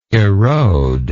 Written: erodability

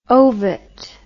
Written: ovett

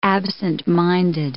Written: absentminded

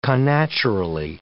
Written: connaturally

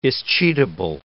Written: escheatable

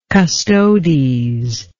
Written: custodes

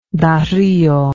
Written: dario

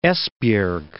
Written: Esbjerg

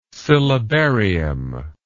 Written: syllabarium

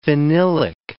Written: phenylic

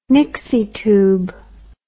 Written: nixie tube

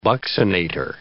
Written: buccinator

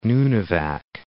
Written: Nunivak

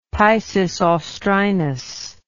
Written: Piscis Austrinus

